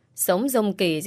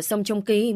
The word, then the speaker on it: Sông Trông Kỳ: Rồi mơ với cái ông Sống Dông Kỳ gì đấy của em đi. Này, anh đọc tên sai rồi đấy. Cái anh đấy phải đọc là Sông Trông Kỳ mới đúng.